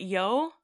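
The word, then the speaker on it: Yeoh: Is it Yeo? – I believe so. – Okay. – Michelle Yeo.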